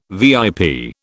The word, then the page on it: VIP: British English